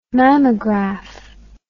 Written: mammograph

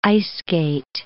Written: ice-skate

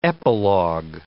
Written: epilogue

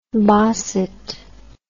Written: bosset

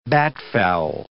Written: batfowl

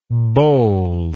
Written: bowllike

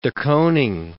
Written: de Kooning

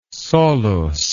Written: solus

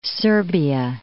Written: Serbia

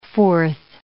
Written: forth

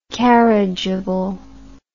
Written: carriageable

